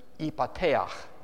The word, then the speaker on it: Ephphatha: In the Aramaic language, this word would have been pronounced "ipateach," okay, "Ephphatha." And Jesus spoke the language of his community.